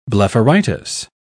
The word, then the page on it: blepharitis: British English